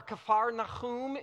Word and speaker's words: Capernaum: We're in a small fishing village called Capernaum, or Capernaum is how it would be pronounced.